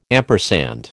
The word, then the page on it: ampersand: American English